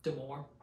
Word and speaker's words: d'Amour: So Outlandos, how did you say it? – Damore.